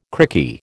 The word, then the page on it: crikey: American English